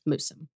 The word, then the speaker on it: Moosham: So, little disclaimer out front is that I have heard and seen Moosham Castle pronounced multiple different ways. I'm just going with the one that I've heard the most frequently, which is Moosham.